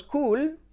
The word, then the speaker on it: cool: Todos decimos cool, pero realmente no va a ser cool, sino cool. ¿Por qué? Por la letra clave L, que lo cambia todo.